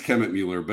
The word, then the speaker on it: Kemmetmueller: Did I pronounce it right? – It's Kemmetmueller. But, you know, with a name like this for my whole life, that's not the worst.